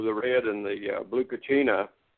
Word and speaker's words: Kachina: Kachinka, or whatever they, you know, whatever the Hopis had to say. – Yeah. – I'm not sure Kachina. – Exactly how you pronounce it.